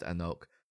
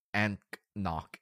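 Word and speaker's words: anCnoc: It's been at the liquor store for a while, and that's not how it looks like it's spelled. But it's pronounced Anouk. – It looks like it's A-N-C-N-O-C. – Yeah. It's more like Anknock.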